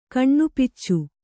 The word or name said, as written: ಕಣ್ಣುಪಿಚ್ಚು